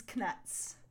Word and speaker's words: Knuts: D's Knuts. – Yes. – And I like to pronounce the K because that's what Jim Dale does, and also because it differentiates knuts from actual nuts.